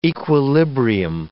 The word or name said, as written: equilibrium